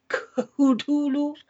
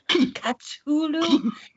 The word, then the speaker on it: Cthulhu: Lovecraft's works are most popular for such terrors as The Great Cthulhu, or, you know, the story The Call of Cthulhu, and you can pronounce it any way you want. Some people say it's Cthulhu, Cthulhu.